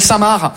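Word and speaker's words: Saint Marc: On est d'accord, les Français, que Samar, ça s'écrit M-A-R-C, comme le prénom Marc. Mais vous, vous mettez un sein devant le prénom Marc, le C, il est parti en grève.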